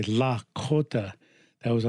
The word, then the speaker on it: Lakota: And to pronounce this correctly is Lakota, Lakota, not Lakota or Dakota or Minnesota. It's Sota. So it'd be Lakota, right? It means something when you say it that way.